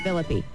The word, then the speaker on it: Phillippe: Do the E. – Phillippe. – Right.